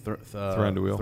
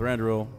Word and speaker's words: Thranduil: Yeah, knows all about this elf history, which is fantastic, and brought up Legolas' father, I can never say his name, Thranduil. – Thranduil, thank you.